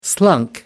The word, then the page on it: slunk: American English